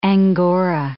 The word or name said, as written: angora